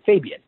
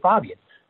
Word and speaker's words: Fabian: Everyone here in the U.S. pronounces it Fabian. – Yeah. – He pronounces his name Fabian.